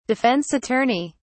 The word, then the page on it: defense attorney: British English